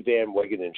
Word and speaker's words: Van Wagenen: this is a Brody Van Wagenen show.